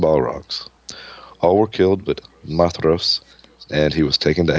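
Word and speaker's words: Maedhros: It's Mydros. – That's right. D-H is a Mydros. T-H. Mydros.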